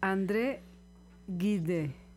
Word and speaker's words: André Gide: Ya me acordé cómo se dice André Gide. – Ah, bueno. – Gide, ya sé.